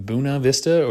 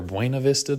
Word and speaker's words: Buena Vista: But I'm coming at you here from Buena Vista or Buena Vista. The locals pronounce it differently here in Virginia on my zero day.